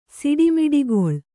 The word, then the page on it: ಸಿಡಿಮಿಡಿಗೊಳ್ಳು: siḍi miḍigoḷḷu